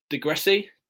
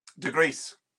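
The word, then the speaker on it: Degryse: Is it De Gressi? – De Gries.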